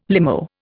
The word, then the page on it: limo: Indian